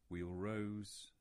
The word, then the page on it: Wheal Rose: English